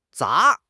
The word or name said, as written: 砸